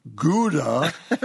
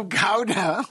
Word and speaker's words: Gouda: She would like me to convey to you that the Dutch cheese is not pronounced Gouda. She says that may be the way it's said in backwoods Wisconsin, but when you and Tom are hiking the trails in your raccoon skin hats, wielding your Bowie knives, she has a vivid and fertile imagination. The correct pronunciation is Gouda.